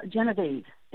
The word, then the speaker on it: Gennieve: G-E-N-N-I-E-V-E is an odd spelling to me. How do you say her name? – Well, Genevieve is how I say her name, okay. – And I say it in the sense of how Americans would have said it.